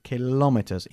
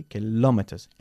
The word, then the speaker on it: kilometers: Good, y nosotros decimos siempre kilometers y no kilometers. Okay, kilometers, enfatizando la segunda sílaba, ¿vale? – Mm-hmm.